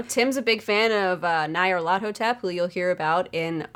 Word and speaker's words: Nyarlathotep: A lot of people say Nyarlathotep, but it's Egyptian. – That's the right way to say it. It's the right way to say it. – Nyarlathotep.